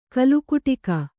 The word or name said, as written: ಕೋಲುಕುಟುಕ